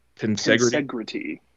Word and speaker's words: Tensegrity: Is this just, would you say that, how do you pronounce the stand? – Tensegrity.